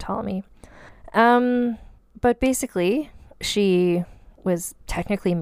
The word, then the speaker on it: Ptolemy: Ptolemy. It's spelled with a P at the beginning, so it's like pterodactyl. And it's hard for me to not say the P.